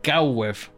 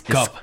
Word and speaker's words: Cobweb: Y, cuyo título original es Cobweb. Cobweb. Que como salimos, yo, yo pensaba que era Cowweb, pero no. no, es, Cobweb. Cobweb.